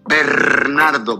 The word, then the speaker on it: Bernardo: Bernardo, Bernardo. If you can't roll your R's, please, please, don't embarrass yourself and just call me Mr. V.